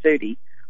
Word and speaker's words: Eudy: Yeah, EU is you, but Sid's family says ooh, so we go along with it. It's Eudy. – So it is ooh. Like Moody. – It is. – Like Moody without the moo.